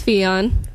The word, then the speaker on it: Fion: My name's Fion, rhymes with neon. You could also say Fiat, but with an N at the end instead. That's how you say my name.